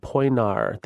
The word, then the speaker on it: Poenari: It's called Poinar. The I at the end is silent. So P O E N A R I.